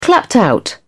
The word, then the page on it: clapped out: British English